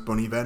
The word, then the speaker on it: Bon Iver: Bon Iver, it's pronounced Bon Iver, not Bon Iver, all right? Yeah, because I'm cool. It's like Good Winter, yeah, that's what I do.